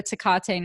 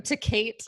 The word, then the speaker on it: Tecate: Am I saying that right? – Yeah, I think so. Is it? – Okay. – What's the alternate pronunciation? – I don't... To Kate? – I don't think it's that.